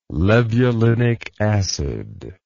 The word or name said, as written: levulinic acid